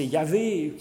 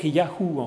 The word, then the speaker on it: Yahou: Ce Yahvé, Donc c'est le tétragramme YHWH qui est parfois prononcé Yahvé, qui se prononçait plus à l'époque Yahou en fait, comme dans le nom, des noms qu'on appelle Théophore, comme Nathan Yahou, qui veut dire Yahvé donne ou a donné.